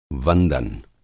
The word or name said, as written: वंदन